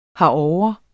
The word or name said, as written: herovre